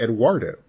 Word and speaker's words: Eduardo: So the way that you said Eduardo right now is how they pronounce it, obviously. Eduardo.